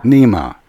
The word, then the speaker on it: Nimeh: Nimeh, okay.